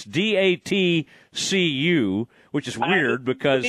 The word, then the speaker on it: DATCU: Yeah, how do we say this? – I believe it is called DATCU Stadium okay, and that's some kind of financial type place or whatever. But okay, DATCU is what we're going to say.